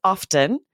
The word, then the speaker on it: often: She pronounces the T like you. She says often.